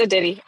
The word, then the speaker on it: Aditi: Aditi, is that how you say your name? – Yes, it's Aditi.